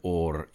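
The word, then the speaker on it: Or (אור): Somehow, some way, the light was in the darkness in the homes, and it's the first time that the word or is used. Again, since Genesis 1. – I love the way you roll your R's, incidentally. Very nice. – Or. – Yes, it's exactly right.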